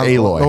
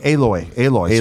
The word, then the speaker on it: Aloy: Did I say it wrong? – I think it's Aloy. – Oh, wait. Aloy.